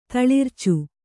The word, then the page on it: ತಳಿರ್ಚು: taḷircu